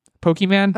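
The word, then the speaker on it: Pokémon: Pokemon.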